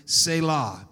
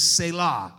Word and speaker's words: Selah: So I'm going to go with what I see on the Hebrew pronunciation, which is Selah. I like that part. You're supposed to put emphasis on the latter part, not the first part.